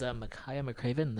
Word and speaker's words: Makaya McCraven: So again, this is Makaya McCraven. That's spelled M-A-K-A-Y-A, M-C-C-R-A-V-E-N. And this was live set from the Red Bull Studio Sessions, which came out on 19th, 2018.